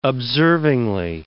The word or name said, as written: observingly